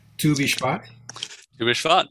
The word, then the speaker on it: Tu Bishvat: Help me with the pronunciation. Tu Bishvat. – Yeah. Good job.